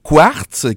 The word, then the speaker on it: QW4RTZ: Oui, on rentre dans cette catégorie-là avec le groupe Quartz qui s'écrit QW4RTZ. Mm-hmm.